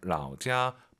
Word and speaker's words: 老家: 삼성일성은요, 선생님을 떠올리세요. 라오시 발음하고 똑같잖아요. 라오지아 발음이 똑같은 게 아니고 성조가 똑같은 거죠. 라오지아, 라오시. 내가 먹어요.